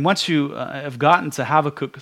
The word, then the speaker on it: Habakkuk: my mind has kept wandering back to the Song of Habakkuk, better pronounced Habakkuk.